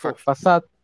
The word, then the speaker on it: façade: ¿cómo se pronuncia esta palabra? Es Fasad.